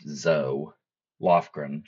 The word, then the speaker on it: Zoe Lofgren: And yesterday at the business meeting of the January 6th Select Committee, Representative Zoe Lofgren, and I may have pronounced her name Zoe yesterday, and if I did, I apologize for that. It's spelled Zoe, and usually I guess Zoe is Zoey, but it's pronounced Zoe Lofgren.